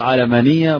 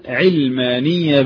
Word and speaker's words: علمانية: قال نفس الكلام، الأصل فيها قياسها على العلمانية وكذا، والحكاية كذا على العالم وتنطق علمانية بالفتح نفس الطريقة، إلا أن إيه؟ تنطق عند أهلها علمانية بالكسر أو علمانية بالفتح.